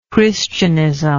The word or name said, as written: Christianism